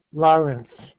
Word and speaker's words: Lawrence: Lawrence.